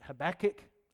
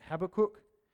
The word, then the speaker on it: Habakkuk: Now, one of the most significant scriptures of faith is found in the Old Testament book of, some pronounce it Habakkuk, others pronounce it Habakkuk.